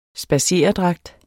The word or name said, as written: spadseredragt